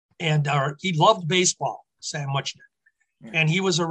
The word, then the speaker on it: Muchnick: You said Muchnick. – I'm sorry. – I think the general. No. That's why we're here. We learned this. The general pronunciation was Muchnick. – Muchnick. Okay. – Just more the way it looks.